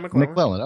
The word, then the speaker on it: McLellan: Is it McClellan? How do you say it? – Yeah, McClellan.